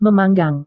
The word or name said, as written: memanggang